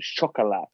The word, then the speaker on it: Chocolat: I think it's pronounced Chocolat. – It is indeed.